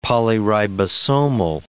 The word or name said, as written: polyribosomal